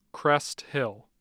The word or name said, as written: Crest Hill